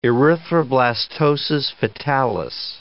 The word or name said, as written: erythroblastosis fetalis